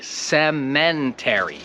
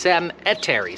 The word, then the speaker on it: cemetery: Ale na pewno nikt nie mówi cementary. Bo to nie jest cemetery, to jest cemetery, cemetery.